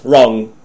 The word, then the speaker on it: wrong: You can't pronounce the W sound and K sound in the word wrong. Okay? It's the opposite of right, wrong.